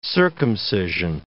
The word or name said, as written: circumcision